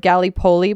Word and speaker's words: Gallipoli: In 1915, invasion of the, I don't know this word, Gallipoli Peninsula. – Yeah. – Did I say that right? – You did.